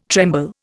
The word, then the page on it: tremble: Indian